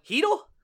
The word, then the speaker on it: Chytil: And we were calling Philip Hithel for a long time, and I liked Hithel, Hithel. But apparently the correct pronunciation all of a sudden is now Hithel? – Hithel.